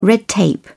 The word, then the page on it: red tape: British English